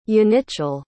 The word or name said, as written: yanichel